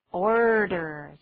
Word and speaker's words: order: 请注意发音哦，很多人念作 odor，odor 就是臭味的意思，这可是 order 加 r，order。